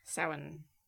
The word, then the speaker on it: Samhain: Okay, but for instance, on Samhain. – Samhain. How do you spell that? Just curious. – S-A-M-H-A-I-N. – So there's no W.